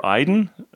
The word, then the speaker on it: Iden: It's called In the Garden of Eden, or Iden. The word is spelled I-D-E-N.